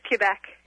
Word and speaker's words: Quebec: Oh, so I was just saying, is it Quebec or Quebec? I never know if I'm saying that correctly. How do you say that? – It's Quebec. – It is Quebec.